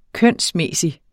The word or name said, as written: kønsmæssig